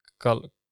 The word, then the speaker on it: Golestan: Yeah, that's Golestan.